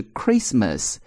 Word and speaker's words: Christmas: 注意圣诞的话就是 Christmas，中间的 t 是不发音的，但是呢前面的词把 mas 去掉， c-h-r-i-s-t 读作 Christ 就是耶稣基督的意思啊，所以呢这两个词发音是有变化的。